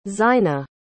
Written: zaynah

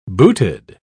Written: booted

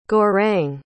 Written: gaurang

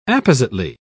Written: appositely

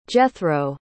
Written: Jethro

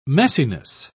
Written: messiness

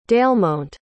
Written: delmont

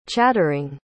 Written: chattering

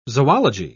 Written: zoology

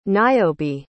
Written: niobe

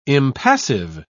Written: impassive

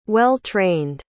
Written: well-trained

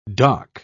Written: doc

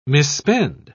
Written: misspend

